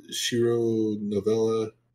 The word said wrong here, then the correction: Shiori Novella: Shiori Novella, okay.